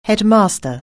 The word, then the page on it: headmaster: anglais